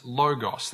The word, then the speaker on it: logos: The word for word is logos. That's the word he's using. I'm probably pronouncing, is that okay? Logos? Okay, good.